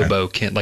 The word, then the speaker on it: Hoboken: Hoboken, like a kin that's a hobo.